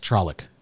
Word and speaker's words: Trolloc: What's Trolloc? – Okay. First of all, it's super nerd stuff. A Trolloc, and you pronounced it correctly,